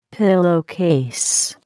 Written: pillowcase

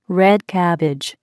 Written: red cabbage

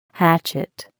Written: hatchet

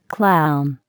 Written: clown

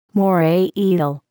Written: moray eel

